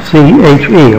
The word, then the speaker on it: CHE: It's actually pronounced C-H-E. – I'm sorry? – It's actually pronounced C-H-E Consulting. – C-H-E, all right.